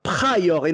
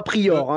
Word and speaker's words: Pryor: Et encore, j'ai fait l'effort de dire Pryor et non pas Prior, hein, quand même.